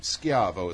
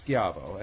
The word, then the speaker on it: Schiavo: Oh, so it's not Shivo like Terry Shivo. – Well, it's actually spelt the exact same way, but it's mispronounced by the Americans. My name is Schiavo, and it's actually the Italian word for slave.